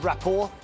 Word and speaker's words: rapport: What letter is silent in the word rapport? – Bonnie. – T. – Correct.